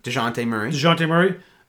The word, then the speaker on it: DeJounte Murray: DeJounte Murray. Did I pronounce that correctly? – DeJounte Murray.